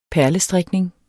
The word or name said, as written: perlestrikning